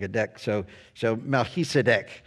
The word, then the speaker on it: Melchizedek: But I will tell you that the actual pronunciation of this obscure figure is Melchizedek.